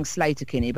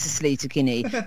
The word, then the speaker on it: Sleater-Kinney: It was published in the New York Times about Sleater-Kinney, about which I spent years pronouncing Slater-Kinney, but I've now finally given in and accepted that it probably is Sleater-Kinney from the States.